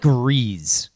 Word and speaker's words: Rhys: is it John Rhys Plumlee or Riz Plumlee? – I think it's like Riz. Like you would pronounce it like it's R-E-E-Z. I could have swore the broadcast said Rhys.